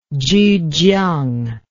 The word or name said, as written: zhujiang